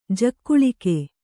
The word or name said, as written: ಜಗುಳಿಕೆ